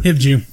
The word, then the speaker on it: Hivju: I don't know how to say his last name, but Christopher Hivju. – Hivju, yeah. That's exactly who I got as well. – I got Fassbender. – Do you? – Just give it to him. – Yeah, Christopher Hivju from Game of Thrones as well.